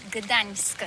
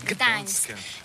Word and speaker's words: Gdańsk: Alors d'abord, comment prononce-t-on bien? Ça s'écrit G-D-A-N-S-K. On dit Gdańsk ou Dansk, on prononce le G ou pas?